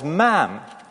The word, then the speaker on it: ma'am: Each subsequent time you speak to her as ma'am, to rhyme with Pam, even though you're not American.